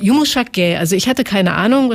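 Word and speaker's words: yumuşak ge: Yumushak Ge, das spricht man fast kaum aus, also R ist es im Türkischen.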